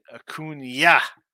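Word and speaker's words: Acuña: Randy says, in Atlanta, we can pronounce it Acuna. Acuna.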